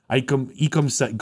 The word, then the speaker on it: Ekwonu: And his name is actually pronounced Ekwonu. Is from the Igbo language and means my strength will not be in vain.